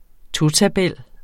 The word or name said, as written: totabel